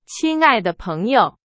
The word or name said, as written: 亲爱